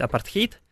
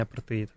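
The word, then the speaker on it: apartheid: Вообще правильно его произносить апартхейт. И в русскоязычной литературе часто так писали, но в итоге перешли на англоязычное произношение апартеид.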